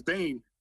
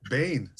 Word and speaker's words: Bain: His name was Bane. – Bane. – So Bane, like, you know, Batman Bane. – Yeah, yeah. I was just, okay. – Yeah, but it's not spelled, it's spelled B-A-I-N.